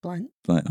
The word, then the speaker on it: Blount: How you say your last name? – Blount. – Oh. – Yeah, I thought that was pretty clear. – Yeah. – You know what I mean? – there's no O.